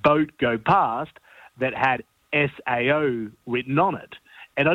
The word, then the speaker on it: Sao: If you're going to be finicky about it, it's got to be pronounced the Sao Biscuit. – As in cow with an S, Sao.